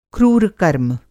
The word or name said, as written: क्रूर कर्म